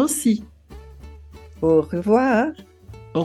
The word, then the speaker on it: aussi: Se escreve A-U-S-S-I, mas a gente fala aussi.